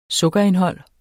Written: sukkerindhold

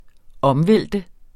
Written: omvælte

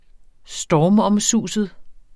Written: stormomsust

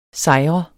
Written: sejr

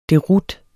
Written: DEROUTERNES

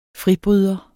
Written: fribryder

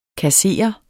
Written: KASSEREDES

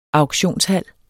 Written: auktionshal

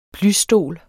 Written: plysstol

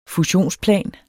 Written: fusionsplan

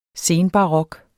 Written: senbarok

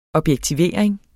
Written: objektivering

